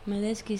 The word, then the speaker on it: Medeski: Sí, pero es que los veía ahí y es que era muy curioso lo que hacía el teclista, pianista, que es Medeski, ¿no? – Medeski, sí.